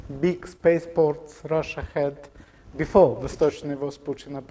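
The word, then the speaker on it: Vostochny (Восточный): Okay, it's pronounced Vostochny. Everybody remember word Vostok? Name of the Yuri Gagarin spaceship.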